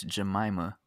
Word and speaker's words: Jemima: Jemima, is that what it is? – I think it's Jemima.